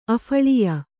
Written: ಅಫಳಿಯ

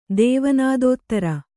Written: ದೇವನಾದೋತ್ತರ

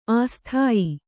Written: ಅಸ್ಥಾಯಿ

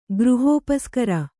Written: ಗೃಹೋಪಸ್ಕರ

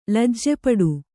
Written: ಲಜ್ಜೆಪಡು

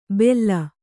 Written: ಬೆಲ್ಲ

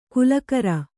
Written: ಕುಲಕರ

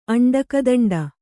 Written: ಅಂಡಕದಂಡ

